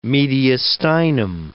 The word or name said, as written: mediastinum